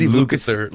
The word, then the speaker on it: Lukather: So, you know, he Lucifer, can't really do anything. – Right? Did I say it right? – Lucifer? – Steve Lukather. – Lukather. Of Toto.